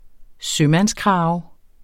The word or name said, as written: sømandskrave